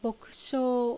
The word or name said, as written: Bokushou